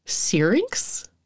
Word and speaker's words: syrinx: And what I learned is that the larynx, or in birds, it's called a, oh no, I know how it's spelled. I don't know how it's pronounced. Syrinx? – Okay. Maybe? That sounds right.